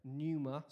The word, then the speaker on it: pneuma: Pneuma is the Greek word, pneuma. Say pneuma. Pneuma. It begins with P, but it's a silent P.